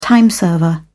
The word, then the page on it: time server: British English